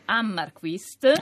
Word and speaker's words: Hammarkvist: Si chiama Ion, adesso cerco di pronunciare bene il suo cognome, Ammarquist. – Ah, hai fatto bene.